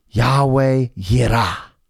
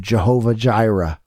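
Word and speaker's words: Yahweh Yirah (יהוה יראה): Yahweh Yirah. Via the German, we've corrupted this to Jehovah Jireh, but the J is actually a Y.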